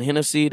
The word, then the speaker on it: Hennesy: My name is Tavon Hennessy. The last name is spelled H-E-N-N-E-S-Y.